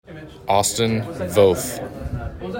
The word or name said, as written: Austin Voth